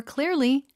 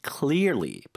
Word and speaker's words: clearly: I was able to understand the problem much more clearly after the meeting. – Much more clearly. 발음 한 번만 더 해주세요. – Much more clearly. – Clearly. – Clearly. – 알 발음, 엘 발음.